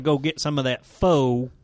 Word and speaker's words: pho: Yeah, I've also been eating a lot at the pho grill that's... – You just said it wrong! Pho!